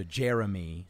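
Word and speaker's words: J'er'em'ih: In reference to Jeremy, spelt J apostrophe E R apostrophe E M apostrophe I H. pronounced like Jeremy from Dimension 20's side quest, Escape from the Blood Keep.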